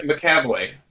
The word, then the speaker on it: McAvoy: McVoy? Something like that. – McAvoy. – McAvoy. That's why there's an extra syllable.